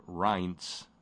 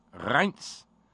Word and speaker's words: Reince: His first name is pronounced with the long I sound, Reince, I guess. Reince!